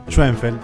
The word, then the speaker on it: Schoenfeld: Schoenfeld, sí.